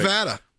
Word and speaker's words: Nevada: Nevada.